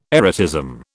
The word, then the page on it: erotism: British English